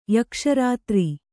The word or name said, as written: ಯಕ್ಷರಾತ್ರಿ